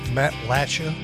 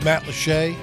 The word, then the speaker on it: Matlacha: So when uh, we were down in uh, Southwest Florida, Matt Matt Latcha is kind of how it's spelled. – Right. It's okay. – Pronounced Matlacha.